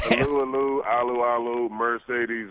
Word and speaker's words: Alualu: Tyson Alualu, or how do you say it? – Tyson Alu Alu. – Yeah, people keep telling me it's Alu Alu, and it's a little less fun for me to say.